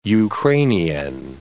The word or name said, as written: Ukrainian